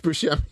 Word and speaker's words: Buscemi: No, it's Buscemi. – Buscemi? Bruschetta? Steve Bruschetta. – No, Steve Buscemi.